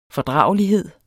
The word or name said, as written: fordragelighed